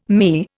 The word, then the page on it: ME: Indian